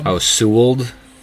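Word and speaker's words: Sewald: They went out and got the closer from the Seattle Mariners, whose name I still cannot pronounce correctly. – Sewold. – Paul Sewold.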